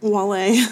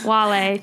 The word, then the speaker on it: Wale: Yeah, Wale. – Wale.